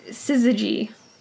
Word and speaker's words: syzygy: It's a syzygy. – Okay. – Yeah. Sorry, it's spelled S-Y-Z-Y-G-Y.